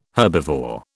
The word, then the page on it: herbivore: British English